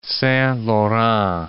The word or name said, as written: Saint Laurent